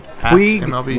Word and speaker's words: Puig: Puig, is that how you pronounce it? Puig? – It's Puig.